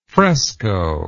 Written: frescoed

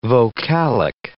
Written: vocalic

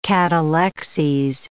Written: catalexes

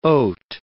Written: oat

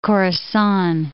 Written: Khorasan

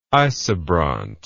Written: isobront

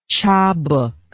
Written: chabouk

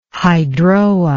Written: hydroa

